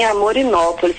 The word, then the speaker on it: Amorinópolis: Falei lá com Humberto de Lagoa de Prata, de Pedra, e agora de Amorinópolis, falei certo? – Sim, Amorinópolis, Fazenda Bela Vista.